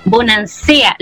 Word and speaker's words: Bonasea: Suele pasar, amiga Lorena, bonancea. ¿Lo dije bien o bonancia? – ¿Sí? ¿Cómo es? Sí, bonancea.